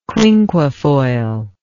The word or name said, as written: quinquefoil